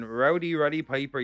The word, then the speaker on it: Rowdy Roddy Piper: Rowdy Roddy Piper.